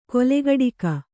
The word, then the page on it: ಕೊಲೆಗಡಿಗ: kolegaḍiga